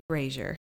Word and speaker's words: brazier: But no, the correct pronunciation would be brazier, just a little fire pit, a fire stand.